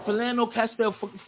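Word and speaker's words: Philando Castile: Fidel Castell. Philando Castell, pardon me, but mispronouncing the name.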